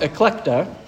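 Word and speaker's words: eklekta (ἐκλεκτή): And the Greek word elect, the Greek word for elect is pronounced eklekta.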